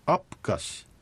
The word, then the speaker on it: アプカシ: これは歩くという単語でして、アプカシ、アプカシという発音です。